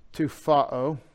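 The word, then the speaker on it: tupho: Tufao. Put the emphasis on that syllable. And that's T-U-P-H-O, long O.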